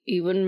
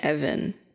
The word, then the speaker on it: Ewan: It's not Evan.